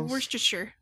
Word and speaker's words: Worcestershire: Yeah, Worcestershire.